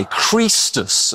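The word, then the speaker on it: Chrestus: Well, Suetonius tells us because they were squabbling over one called Christus, spelt with an E in Suetonius's work, and it's pronounced with a long E, so very like how it would have been pronounced if it was an I, Christus as well.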